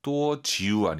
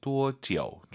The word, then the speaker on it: 多久: 多久 아니고 아이유 사이 오너서 多久.